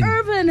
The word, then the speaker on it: Irvin: It's I-V-A-N. That's how you pronounce Ivan. – It's fine. – Irvin has got an R. – My man like an Irvin. – Respect the R.